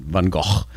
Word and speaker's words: Van Gogh: That's how you pronounce it in Holland. Van Gogh.